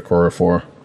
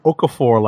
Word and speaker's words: Okorafor: I said Dr. Nnedi Okorafor. – Right. I kept wanting to call her Okafor, like all the basketball players who are named Okafor. But Okorafor writes this book, and like you said, kind of fills in that blank.